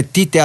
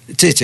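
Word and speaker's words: Tite: Δεν ξέρω αν η προφορά στα βραζιλιάνικα είναι Τσίτσι. Εδώ τον λέμε Τίτε, αλλά εγώ πιστεύω ότι είναι Τσίτσι. Δεν ξέρω εσείς η προφορά. Τσίτσι, έτσι. Ναι.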